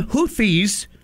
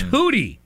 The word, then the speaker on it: Houthi: Yeah, that's why I call them Houthis. – Yes. – The terrorist ones I call Houthi, even though that's not apparently the correct pronunciation. You don't pronounce the TH. Mm. It's Hootie, the Hooties.